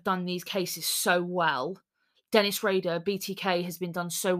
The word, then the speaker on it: Dennis Rader: Denise Rader? – Dennis Rader.